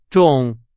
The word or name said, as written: 种